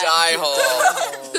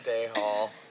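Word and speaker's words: Dey Hall: Die Hall. So. Not Day Hall.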